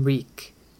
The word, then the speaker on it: Reek: Theon Greyjoy might have tried to help her once, but Theon had been ironborn, and a braver man than Reek. Reek, Reek. It rhymes with weak.